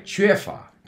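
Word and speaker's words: chue fa (缺乏): I remember the Chinese sound chue, like chue fa. Chue fa is to lack. Chue. We don't have that in English.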